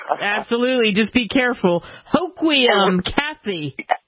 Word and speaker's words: Hoquiam: Hoquiam. – I love that name, Hoquiam. – Yeah, H-O-Q-U-I-A-M.